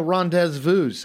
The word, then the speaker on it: rendezvous: That's where we were going to rendezvous, I believe is how you say that. – Nailed it.